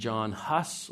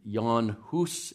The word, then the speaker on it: Jan Hus: And then John Huss, or Jan Hus, if you want to say his name a little bit more accurately, but John Huss all the way in the 15th century.